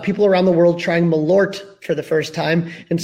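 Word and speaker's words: Malört: I just realized Corey Furlong points out that I forget that everybody doesn't – you know, everyone remembers that I don't pronounce the T in Malore. – Malore. Malore, L-O-L. Yeah, it should be like Malort. Malort.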